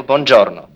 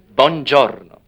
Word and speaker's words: Buongiorno: That's right, Michael Buongiorno. – Buongiorno. – You must roll the R. – You roll the R, huh? Right.